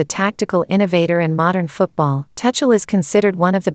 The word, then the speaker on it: Tuchel: Thomas Tuchel, German pronunciation, Thomas Tuchel,